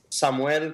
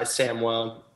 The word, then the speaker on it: Samuel: Technically, it's Samuel because that's like the Spanish pronunciation. But in America, we go by Samuel most most of the time. Uh, my closer friends call me Samuel, but Samuel is perfect.